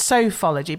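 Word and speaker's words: Sofology: Surely it's Sophology, because you want to hear the sofa bit, don't you? – There's a Sophology.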